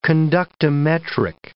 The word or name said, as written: conductometric